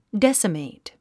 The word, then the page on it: decimate: désəmèit; デッサメイト